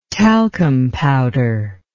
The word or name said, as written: talcum powder